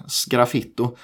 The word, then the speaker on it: sgraffito: Och just eh, Ferdinand Boberg använde en typ av dekor som heter, jag vet ju hur det stavas men jag tycker det är svårt att uttala det, men sgraffito, S-G-R-A, sgraffito är det väl.